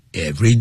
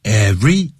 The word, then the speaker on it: every: 好，接着我们再来看一下 ,drivers 说了 ,yes, it will be hot and sunny every day. Every, 也是念成 every, 不要念成 every, 好，那是 every day.